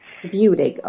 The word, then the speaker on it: Budig: Budig.